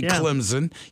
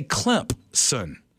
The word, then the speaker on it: Clemson: People that live here know when you say that school 30 minutes down the road, there's an invisible P in the pronunciation. You don't say Clemson. You say Clemson.